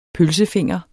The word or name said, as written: pølsefinger